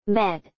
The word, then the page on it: bed: [bed]